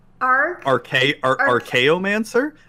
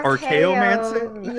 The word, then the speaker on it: Archaeomancer: Zuby just murdered the pronunciation of Arachiomancer. Is that not how you say it? Arachiomancer? How do you pronounce the name? Oh, shit. – Archaeomancer? – Is it Archaeomancer? – Yeah.